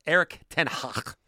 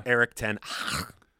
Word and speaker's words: Erik Ten Hag: 90th minute, and the fact that Aaron Te- Erik Ten Hag, that's how you uh, pronounce his name. – Yeah, gotcha. – Erik Ten Hag.